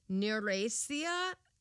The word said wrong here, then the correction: neurasthenia: I'm just looking at the pronunciation guide. Yep. Neurasthenia.